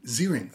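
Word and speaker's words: Ziering: Zierling.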